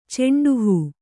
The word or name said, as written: ಚೆಂಡುಹೂ